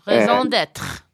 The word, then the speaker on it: raison d'être: That's raison d'etre, if you will. If I actually pronounce that correctly. – Raison d'être. – Oh, I'm sorry. You're closer.